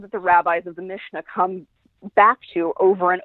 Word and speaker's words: Mishnah: um, Mishnah. Did I say that correctly? – The Mishnah, yes.